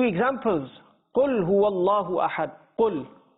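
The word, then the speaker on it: Qul (قل): The letter qaf with the two dots on top. This is pronounced with the full mouth. It's pronounced in a thick tone of voice. Instead of saying qul, if somebody just says the normal kaf, qul, he's changed the meaning.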